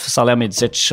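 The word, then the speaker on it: Salihamidžić: Salihamidzic. – Salihamidzic.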